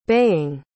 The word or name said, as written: baying